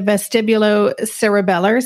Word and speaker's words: vestibulocerebellar: One of the things that you mentioned in the book I thought was really interesting was about the, I'm going to say this incorrectly, the vestibulocerebellar system. – Very good. You said it properly.